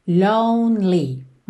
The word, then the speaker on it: lonely: Atenção a essa pronúncia da palavra lonely, ok? Que esse E do meio não é pronunciado. Então, você vai do N pro L, lonely.